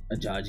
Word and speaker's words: Ajayi: I don't know how to pronounce his last name. – Sure. – Jay Ajayi. – Ajayi, there you go.